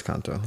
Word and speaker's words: Encanto: So, but how are they going to know that Encanto, however people are pronouncing it. – I think it's Encanto.